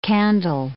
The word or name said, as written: candle